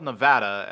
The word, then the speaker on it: Nevada: Infamously, there is the city in the southwestern part of the state that is spelled Nevada, as in the state Las Vegas is in. However, it is pronounced with a hard A sound on the first A, as in Nevada.